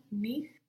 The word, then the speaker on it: nicht: So you can have a strong T on nicht, because you will hear that T in German, you will pronounce it, but without the Nicht. – ch. Nicht. Very good.